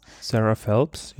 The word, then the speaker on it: Sarah Phelps: Spricht man so aus? Sarah Phelps, ja. Phelps.